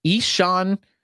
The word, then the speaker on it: Ixan: Now, if you look at it, it's not spelled this way at all. He's actually spelled I-X-A-N, but the correct pronunciation is Ishan, like Ishan. So it's I-X-A-N, Henderson, like normal Henderson, left-handed pitcher in the Cardinals organization.